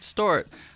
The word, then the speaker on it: DIXRT: It's DIXRT. I know it's confusing. Yeah, it is. But it's pronounced Distort.